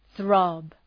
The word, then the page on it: throb: ɵrɒb